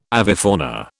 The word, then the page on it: avifauna: British English